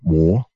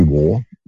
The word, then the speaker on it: Waugh: It's L-E-B-E-D-O-F-F. It's called The Same Man in Love and War. And it's about Evelyn Waugh, by coincidence, and that's the way you pronounce his name, like Jesse Waugh, W-A-U-G-H.